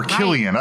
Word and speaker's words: Cillian: Cillian Murphy. Yeah. – Or Killian. I've heard it pronounced Killian as well.